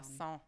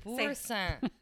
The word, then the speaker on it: Boursin: Boursin. – How are you? Why are you saying it like that if it ends in I-N? Say it again. – Boursin.